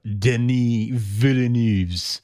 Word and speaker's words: Denis Villeneuve: Let's talk about Denis Villeneuve's latest outing. Did I say that right? You're more French than I am. – You did.